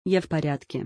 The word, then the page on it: Я в порядке: Russian